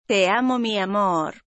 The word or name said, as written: te amo mi amor